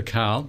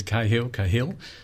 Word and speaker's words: Cahill: I prefer Carl, but it gets Cahill. So it's right across the board.